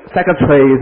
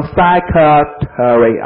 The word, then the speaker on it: secretary: Dormitory 我们这实验室怎么说的 Lab 全称是什么，大家注意后面不是 Laboratory 啊，而是 Laboratory 分开的啊 Laboratory 我们说秘书怎么说的？Secretary 是吧？那么要注意一下，美国人他这样读分开了，秘书的是读成 secretary 啊，secretary，dormitory，laboratory。